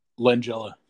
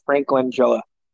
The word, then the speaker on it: Langella: Frank Langella? – Langella. – Langella. Frank Langella.